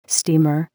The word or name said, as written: steamer